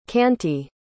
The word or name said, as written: canty